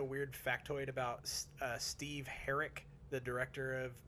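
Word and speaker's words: Herek: So Steve Herrick, I think is how you pronounce his name. – Yeah, it is.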